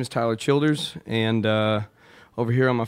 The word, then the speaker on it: Tyler Childers: And in the meantime, we're going to talk to our friend Tyle Childress. Is that, did I say it right? – Tyler Childers.